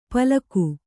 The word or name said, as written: ಪಲಕು